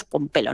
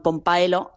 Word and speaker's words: Pompaelo: Entonces el nombre correcto es Pompelo, no Pompaelo, que se escribe así porque los pompelonenses en la inscripción son pompaelonenses con esta AE que te digo que existe en latín, pero que se debe de pronunciar como E, no como AE.